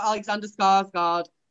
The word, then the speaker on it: Skarsgård: It was the um Skarsgård, Alexander Skarsgård.